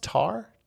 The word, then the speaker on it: Tár: So I think this movie's pronounced Tar, T-A-R. It's a T like with a little like accent over it. Tar.